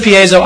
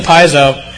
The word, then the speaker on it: piezo: It uses, okay, how do you pronounce it? Piezo. He says piezo, I said piezo.